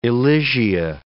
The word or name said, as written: Elysia